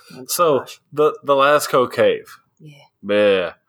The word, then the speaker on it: Lascaux: Uh, but it is sp- uh, pronounced uh, Lascaux, La- Lasco. – Lasco.